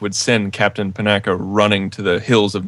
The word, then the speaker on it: Panaka: It is Bosk versus Captain Patanka. Isn't that how you say his name? – Panaka, whatever.